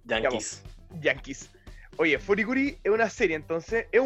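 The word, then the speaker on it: FLCL: Esta, bueno, esta serie tiene como hartas pronunciaciones, depende de dónde sea, pero yo le voy a decir Furikuri porque así se dice en Japón y prefiero decirle así que como le dicen los gringos.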